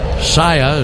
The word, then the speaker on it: Saia: Saia is the official freight carrier of the Atlanta Braves. S-A-I-A. You've seen their trucks before. Pronounced Saia.